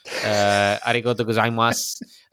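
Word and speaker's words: arigato gozaimasu (ありがとうございます): arigato gozaimasu. they can. That's my Japanese right there. – You actually pronounced it pretty decent, I have to say.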